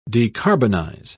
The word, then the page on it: decarbonize: British English